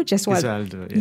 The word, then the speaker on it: Gesualdo: Gesualdo, yeah.